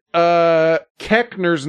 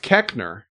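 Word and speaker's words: Koechner: Keckner is right from what I remember, but it's spelled so much differently. It's K-O-E-C-H-N-E-R-S, so it whatever, but it is Keckner.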